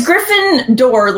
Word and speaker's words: Gryffindor: It's Gryffindor, like a wooden door.